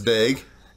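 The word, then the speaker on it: Daeg: The young Michael Myers in this, his name is pronounced Daig, I guess. We never really know. – We're pretty sure it's Daig.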